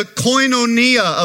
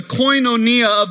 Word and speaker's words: koinonia: If I were to say it in Greek, I would say it like this, that the koinonia of thy faith, the koinonia of thy faith.